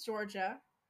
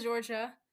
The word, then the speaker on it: Georgia: My name is Georgia, like the state. Some people think it's spelled like J-O-R-J-I-A.